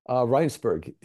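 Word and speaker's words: Rijnsburg: Did he moved to Regensburg, right? – Reinsberg, yes. It's the Dutch pronunciation.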